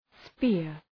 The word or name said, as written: spear